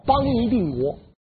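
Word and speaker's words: 邦泥定国: 党项羽的发音叫邦尼定国。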